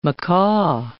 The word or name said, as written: macaw